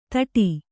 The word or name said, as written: ತೊಟ್ಟು